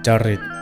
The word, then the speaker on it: จริต: อ่านว่าจริต